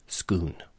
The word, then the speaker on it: Scone: but I have to let you know that the Scottish location here is pronounced Scone.